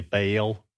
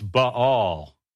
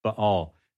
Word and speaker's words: Baal: We, you know, in Southern Baptist churches, we say Baal, you know, they worship Baal. Okay. That's not how you pronounce it. It's Baal. It's a diphthong. It's Ba, and there's a little silence there, and it's all, Baal.